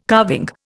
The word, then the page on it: carving: Indian